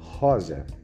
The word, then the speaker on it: rosa: Next one is rosa. Rosa, also just family. In rosas, plural. Rosa. Rosas. So, remember, R in the beginning, use your trove to pronounce rosa. And S between two vowels is Z.